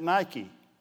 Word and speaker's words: Nike: N-I-K-E. We pronounce it Nike. It's a Greek word in the New Testament. And it's only in there one time in the entire Bible. One time. It's really pronounced Nike.